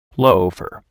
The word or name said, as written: loafer